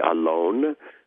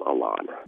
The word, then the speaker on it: Alon: Well, if you said it in Hebrew, it would be Alon, but I tend to anglicize it, and I just call him Alon.